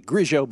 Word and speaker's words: Grillo: All right, we'll go with Grillo. – I'm usually pretty good with names, but he's one I feel bad about almost every time I say it. – Yeah, the Argentine pronunciation should be Grigio, but oh well.